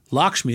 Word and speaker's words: LXMI: She's the founder and CEO of two companies, Samasource and Lakshmi. Lakshmi is spelled LXMI, by the way.